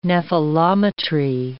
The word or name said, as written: nephelometry